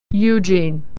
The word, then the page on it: Eugene: /y/